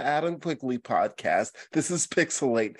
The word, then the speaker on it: PixelHate: Welcome to Pixelate, where the H is silent, but the hate is real.